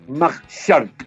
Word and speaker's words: Majchrzak: Kamil Majchark, se dice. – Listo. – M-A-J-C-H-R-Z-A-K de kilo. Bueno, una pronunciación cercana, me imagino, al polaco, habremos dicho. – No, pero está perfecto,